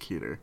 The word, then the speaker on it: Keter: Yeah, it's an object class Keter. Keter? – I think Keter. I've heard it both ways. – Pronounced Keter.